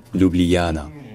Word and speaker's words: Ljubljana: Alors ce qu'on a fait en français, c'est que pour rendre un ça un peu plus facile, on sépare ça en quatre syllabes au lieu de trois. Donc ça devient Ljubljana. Ljubljana.